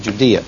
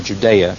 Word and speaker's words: Judea: Judea, or Judea, if you like that pronunciation better.